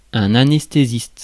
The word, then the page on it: anesthésiste: /a.nɛs.te.zist/